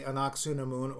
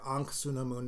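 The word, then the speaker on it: Ankhesenamun: But anyway, he believes that his beloved, the ever so lovely Anaksunamun, or Ankhsunamun, depending on how you want to pronounce it, has been reincarnated in the guise of this 1930s young lady whose name I have forgotten and didn't write down because stupid.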